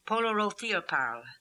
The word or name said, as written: Polorotheoparl